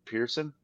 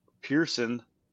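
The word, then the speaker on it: Pierson: So Parker Cootie, and it's like it's Pierce and then Son. I don't know if that's how they spell Pearson. I don't know. We'll have to listen to how they say it on Golf Channel. It's like the word Pierce and then the word Son.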